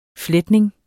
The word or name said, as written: fletning